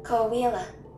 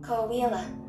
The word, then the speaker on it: Coahuila: Koala. – You hear her? – Koala. – Koala.